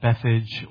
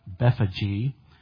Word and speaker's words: Bethphage: And when they drew nigh unto Jerusalem and were come to Bethphage, some people pronounce that Bethage or Bethphage,